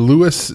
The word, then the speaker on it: Lewes: I am in Lewes, Delaware, and interestingly, Lewes is spelled L E W E S, which I thought was weird.